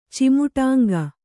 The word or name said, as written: ಚಿಮುಟಾಂಗ